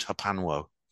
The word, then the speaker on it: HPANW: HPANW. I just pronounce it Hapanwo.